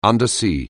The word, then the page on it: undersea: anglais